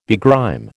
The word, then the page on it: begrime: American English